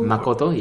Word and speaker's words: Makoto: Makoto, yes. – How do you spell it? – K-O-T-O. – K-O-T-O, yeah.